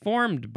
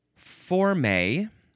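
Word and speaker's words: formée: Next is forme, or forme. You can emphasize either syllable. It is spelled F-O-R-M-E-E, and the first E has the acute accent that goes up on the right.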